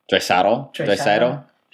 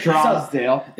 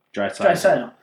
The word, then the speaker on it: Draisaitl: Draisaitl. – No, I'll put it away. – Drysdale. – I actually remember it. It's dry, like D-R-Y. Dry. – Dry. – Sigh. – Sigh. Toll. – Draisaitl.